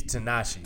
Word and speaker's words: Tinashe: Anybody that sings that many things, you don't say his name the right way. He's Tinashe. – Tinashe. – You got to make it a little softer.